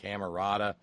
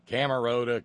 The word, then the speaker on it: Camerota: He says it's pronounced Camerota. Camerota, Camerota, tomato, tomato, potato, potato.